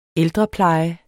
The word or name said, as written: ældrepleje